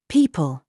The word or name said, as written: LIE